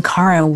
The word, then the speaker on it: Karin: Remember that Karen is spelled K-A-R-I-N. Now, back to Shift Happens. – Hello, everybody, and welcome back to Shift Happens. I am Karen Weary,